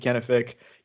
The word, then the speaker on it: Kenefick: Um, my name is Murphy Kenefick.